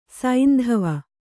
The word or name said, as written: ಸೈಂದವ